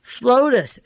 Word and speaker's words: Flotus: I better spell it, because if I say it wrong, it's going to sound wrong. He's Plotus, P-L-O-T-U-S, and she's Flotus, F-L-O-T-U-S. I'm not going to put an A there instead of the O, but you get Plotus and Flotus is Ronald and Nancy.